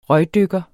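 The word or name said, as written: RØGDYKKERE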